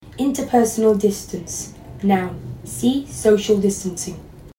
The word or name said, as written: interpersonal distance